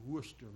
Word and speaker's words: Worcester: Well, I stayed in a hotel in Worcester, Massachusetts. Looks like Worcestershire, almost like the sauce, but it's pronounced a little different.